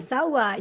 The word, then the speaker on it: Sawa: I think Sawa, am I saying it right? – Sawa, yes.